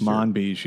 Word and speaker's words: Manbij: and am I saying it right? Manbij? Manbij, yeah. uh, Syria.